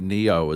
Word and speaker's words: Nioh: They did Nioh as well.